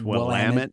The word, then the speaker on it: Willamette: How do you pronounce Willamette? That is your answer. Willamette, damn it.